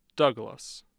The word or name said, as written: douglas